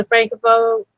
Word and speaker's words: Francophone: Can I ask a little bit about like what the difference is and the dividing lines between like the Francophone? I'm probably not saying that right. – Yeah, it's right.